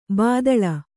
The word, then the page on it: ಭೂತಾಳ: Kannada